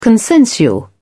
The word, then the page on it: consensual: British English